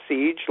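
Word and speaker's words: Sige': And I usually don't, I don't use the Jedi part, but people have a real problem with my name, my nickname, Siege. And it's S-I-G-E with a little apostrophe after the E, and it's pronounced Siege like a siege on a castle.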